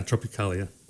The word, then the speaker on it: Tropicália: And the primary genre of this first set, and correct me if I'm pronouncing it, Tropicália. – Yeah, Tropicália. Uh-huh.